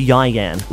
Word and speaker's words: Ieuan: He spells his name I-E-U-A-N. – Is it pronounced Ewan? – I thought it was Ewan, and then I got berated because it's actually Yian.